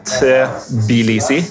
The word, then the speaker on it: Tbilisi: uh, naturligvis så hørte jeg noen lokale uttale det ordentlig. Og det var noe sånn Tbilisi eller noe sånt.